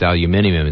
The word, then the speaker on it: aluminium: Well, that's because in Australia it's aluminium, it's not aluminum.